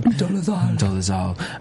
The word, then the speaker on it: Dolezal: So, Rachel Dolezal, however the hell you say her name. – Dolezal.